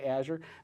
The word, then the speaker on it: Azure: So for those of you that are home wondering how you pronounce these things, Luis, L-U-I-S, Language Understanding Intelligence Services, pronounced Luis. You'll notice that I'm going to be saying Azure. – Not Azure. – Not Azure, which is fine, but if you want to pronounce it correctly, you'll say Azure.